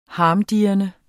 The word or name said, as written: harmdirrende